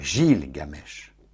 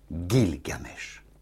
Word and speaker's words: Gilgamesh: Un peu compliqué à prononcer, n'est-ce pas? Pas Gilgamesh, mais Gilgamesh. C'est un nom sumérien.